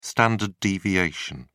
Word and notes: standard deviation: British English

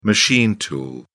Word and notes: machine tool: British English

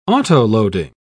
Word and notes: autoloading: British English

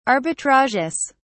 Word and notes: arbitrages: British English

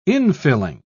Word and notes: infilling: British English

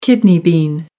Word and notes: kidney bean: American English

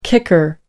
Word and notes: kicker: British English

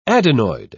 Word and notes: adenoid: British English

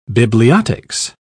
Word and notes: bibliotics: British English

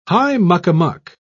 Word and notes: high-muck-a-muck: British English

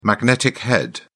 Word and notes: magnetic head: British English

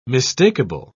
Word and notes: mistakable: British English

